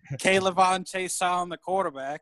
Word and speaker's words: K'Lavon: K'Lavon, is that how you say it correctly? – Yes. – Yep, K'Lavon Chaisson.